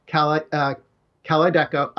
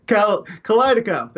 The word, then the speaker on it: Kalydeco: So there are two drugs that they have on the market right now, Kalydeco. I always pronounce that wrong. Kalydeco, thank you.